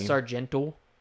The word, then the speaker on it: Sargento: You can get the Sargento. That's how they say it.